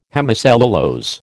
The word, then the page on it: hemicellulose: American English